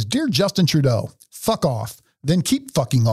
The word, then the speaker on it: Trudeau: Trudeau. I think you're supposed to say his name like you have marbles in your mouth. – Trudeau.